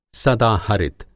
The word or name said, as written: सदाहरित